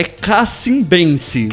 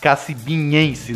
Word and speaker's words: Cacimbense: Como é que é? É cacimbense. – Cacimbense, não ia acertar. – Eu falei, já acertou, cara. – Não, eu fiquei na última sílaba do nhas. Cacimbinhense, né?